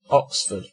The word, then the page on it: oxford: British English